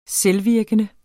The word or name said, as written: selvvirkende